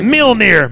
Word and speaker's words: Mjolnir: Yeah. Mjolnir. It's Mjolnir. Mjolnir to me. – Silent J, Jackass.